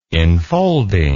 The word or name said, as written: infolding